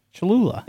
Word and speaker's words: Cholula: You don't know what Cholula is? – Of course I know what it is. – Is that how you say it? – Cholula. – Yeah. Yes.